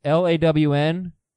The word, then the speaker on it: Long Island: John from Long Island, pronounced LAWN-GUYLAN.